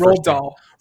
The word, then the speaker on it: Roald Dahl: Roald Dahl.